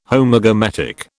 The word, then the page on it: Homogametic: British English